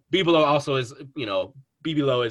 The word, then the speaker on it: Bebelo: Bibelo, I think, is actually the pronunciation. – Bibelo. – I thought Steve McPherson tweeted out that it was Bibelo. – Yeah, in Spanish, it wouldn't be BB. It would be Bebe.